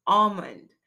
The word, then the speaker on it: almond: So the normal pronunciation is almond because the L is usually silent.